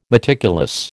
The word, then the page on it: meticulous: American English